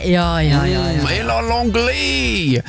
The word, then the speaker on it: Ella Langley: Ella Langley, som hvis du skal uttale helt riktig. – Ja, unnskyld. – Eller hvis du fra, vet du ikke, kan være at du har fransk ane, da er det Ella Langley.